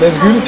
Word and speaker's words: NZGL: Tu peux prononcer Nazgul si tu veux.